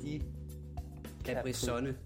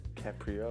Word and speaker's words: DiCaprio: Leonardo Di Caprisonne. – Caprio.